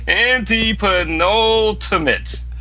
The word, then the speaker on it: antepenultimate: Antipanopinet. Oh, I got screwed. – Not even close. Read the actual thing. Read the actual. – Antipanopinet. It's a tongue twister. Antepenultimate. – Yeah, now, one thing I want to know is it's A-N-T-E-E and then P-I.